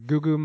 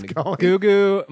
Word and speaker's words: Gugu: Gugu Mbatha-Raw. I don't know if it's pronounced Gugu. Her name is G-U-G-U. I think that's Gugu.